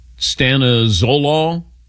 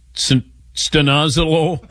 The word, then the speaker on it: stanozolol: Stanozolol, or Dylan, you got a better pronunciation on that? – That sounds about right. – Stanozolol.